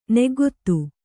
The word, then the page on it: ನಗ್ಗೆತ್ತು: Kannada